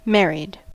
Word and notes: married: American English

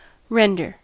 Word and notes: render: American English

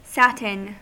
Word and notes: satin: American English

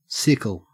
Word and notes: sickle: Australian English

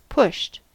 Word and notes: pushed: American English

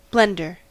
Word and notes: blender: American English

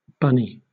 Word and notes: bunny: British English

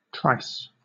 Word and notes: trice: British English

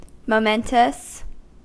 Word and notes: momentous: American English

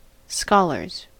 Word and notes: scholars: American English

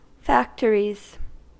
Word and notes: factories: American English

